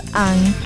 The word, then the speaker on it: Ong: Spelled O-N-G. – Yeah. So if you like them, follow them on Twitter, Instagram. That's Ang the band. Actually, Ong. It's O-N-G, but it's pronounced as Ang the band.